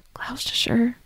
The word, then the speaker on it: Gloucestershire: Gloucestershire. – Gloucestershire. You know what I'm talking about, like Gloucestershire.